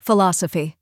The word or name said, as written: philosophy